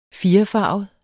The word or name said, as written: firefarvet